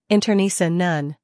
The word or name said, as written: internecine